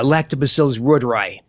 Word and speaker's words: Lactobacillus reuteri: I think if I got the pronunciation correct, it's L. reuteri. Is that the correct pronunciation? – Yeah, Lactobacillus reuteri.